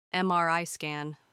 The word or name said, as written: MRI scan